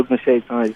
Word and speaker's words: Ogunseitan: Everyone calls me Deli, so that's Oladele Ogunseitan.